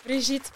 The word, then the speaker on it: Brigitte: Brigitte Perenyi.